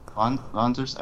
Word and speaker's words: Wanzers: It's actually Wanzers, because like, they're pronouncing it with a German accent. – Okay. – Wanzers? – Okay. Anything with W is pronounced with a V sound.